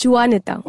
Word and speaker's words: Juanita: It starts with J. The J is silent there. – Right. – So I would read it as Juanita.